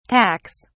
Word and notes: tacks: British English

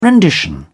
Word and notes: rendition: British English

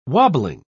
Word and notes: wobbling: British English

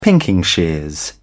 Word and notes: pinking shears: British English

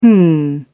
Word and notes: hm: British English

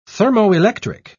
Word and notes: thermoelectric: British English

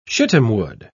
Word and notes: shittimwood: British English

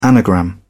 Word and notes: anagram: British English

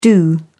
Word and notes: do: British English

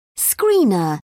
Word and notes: screener: British English

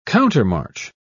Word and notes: countermarch: British English

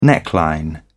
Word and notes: neckline: British English

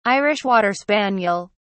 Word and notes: irish water spaniel: British English

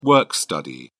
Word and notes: work study: British English

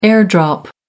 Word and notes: air-drop: American English

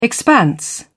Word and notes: expanse: British English

